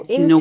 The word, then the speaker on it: Gatineau: Gatineau, Quebec.